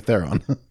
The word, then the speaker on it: Theron: Which is actually a spell, which is actually how you pronounce it. – I know. – She corrected someone in an interview, and everyone's like the whole world's like, what the fuck? And we've all been saying Theron.